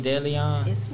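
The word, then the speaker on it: DeLeón: That's how you say it, Deleon? – Yeah.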